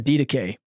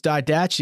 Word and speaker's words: Didache: I said, I don't know how you say it, but it looks like did ache. And I don't know if it's Didache. He goes, Didache.